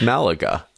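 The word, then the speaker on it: Málaga: According to Google's pronunciation guide, it's Malaga.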